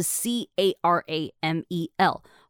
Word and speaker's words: caramel: 后面的那个呃，它的拼法是 c a r a m e l，后面那个 m e l 我自己是发出来，我念成 mel，有些人呢是念 mol，好 caramel 或者是 caramel。都可以，所以说啊，在这个部分，你就会发现同一种字啊，其实对于很多不同的人或是不同地缘性的人来讲，都会有很多不同种念法。